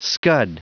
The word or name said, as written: scud